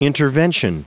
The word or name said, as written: intervention